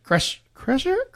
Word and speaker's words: Kreischer: Kreischer. Kreischer? – Yeah.